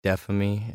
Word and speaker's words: Dephamy: Under the name of Dephamy. It's spelled D-E-P-H-A-M-Y.